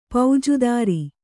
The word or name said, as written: ಪೌಜುದಾರಿ